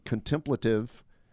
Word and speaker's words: contemplative: I mean, it's a much more contemplative. I don't know how you say that word. – You said it right.